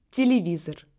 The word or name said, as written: tele